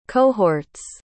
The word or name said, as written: cohorts